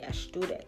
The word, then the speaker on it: Ashdodith: That's how you say Ashdodith.